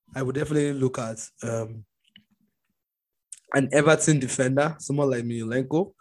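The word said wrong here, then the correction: Mykolenko: or I know I definitely pronounce that shit well, but Mikolenko. – Shit, now save day. So Mikolenko, Richarlison, obviously, Zaha is pretty obvious.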